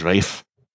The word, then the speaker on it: Ralph: It was Rafe.